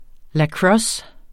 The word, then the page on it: lacrosse: [ laˈkɹʌs ]; [ laˈkʁʌs ]